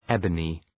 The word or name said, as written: ebony